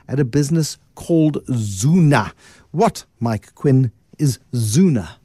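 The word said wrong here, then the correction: Zoona: Zuna is actually pronounced Zona.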